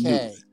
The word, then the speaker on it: Newth: A silent K. – Newth. Gotcha. Gotcha. – Yeah, gotcha. – The K is silent. Yeah.